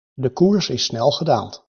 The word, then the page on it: koers: Dutch